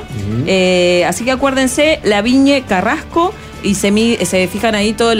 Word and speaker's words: Lavigne Carrasco: También pueden chequear en el Instagram, que es Laviñe Carrasco, se escribe LAVIGNE Carrasco, y van a ver ahí toda la info que tienen.